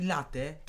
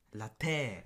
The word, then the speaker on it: latte: I ja wtedy doszedłem do wniosku, że jeżeli ktoś mówi latę, to jest błąd, w ogóle tak nie powinno się mu wymawiać. – Bo latę Tak. ma E jako akcent, więc to jest Latte.